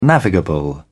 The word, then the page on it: navigable: British English